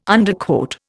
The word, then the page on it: Underquote: Indian